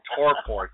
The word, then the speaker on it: torpor: Yeah, well, torpor. T-O-R-P-O-R. Come on, at least say it right, for crying out loud.